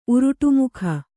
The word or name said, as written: ಉರುಟುಮುಖ